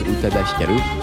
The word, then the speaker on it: Utada Hikaru: J'arrive pas à la prononcer à la française, hein. J'arrive, je la prononce à la japonaise, Utada Hikaru.